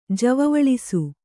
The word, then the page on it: ಜವವಳಿಸು: kannada